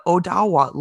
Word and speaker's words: Odawa: North Perth sits on land traditionally by the Anishinaabewaki peoples of the Mississauga, Mississaugas of the Credit First Nations, and the Odawa land. Now, last week I said that wrong. I said Ottawa, O-D-A-W-A, because I'm dyslexic. But then I looked up the proper pronunciation. It's Odawa.